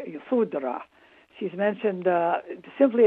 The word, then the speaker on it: Yashodhara: Her name, by the way, is, it can be pronounced Yashodhara or Yasodhara. Which one do you prefer? – I usually just pronounce it Yasodhara.